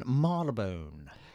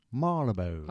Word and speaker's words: Marylebone: This lady loves the station Marylebone. – See, that's an interesting one because how is it pronounced? – Marylebone. – I know, but it's not the way it's written. – Marylebone.